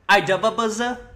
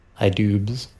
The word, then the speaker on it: iDubbbz: You know how to fucking say iDubbbz. You're so fucking... iDubbbz, I think. It's 2 syllables!